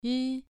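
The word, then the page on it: ri: tone 2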